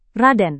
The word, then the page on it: raden: Indonesian